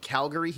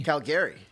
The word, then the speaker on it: Calgary: Calgary. He puts the emphasis on the the oh, back. Oh, Calgary. Of, yeah, instead of Calgary.